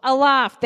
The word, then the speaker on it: aloft: Aloof? Aloof? I don't know how to say that word. Sometimes I grew up in Africa, so sometimes words confuse me. How do you say that word? Aloft, there you go.